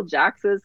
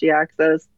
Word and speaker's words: Jhiaxus: We got the villain of G2 comics, Jhiaxus, who I always call Jaxus because that seems more like a name, but it's supposed to be pronounced Jhiaxus.